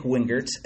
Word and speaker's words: Wingert: I am at Mick Wingert, and the last name is spelled like wing with an E-R-T at the end.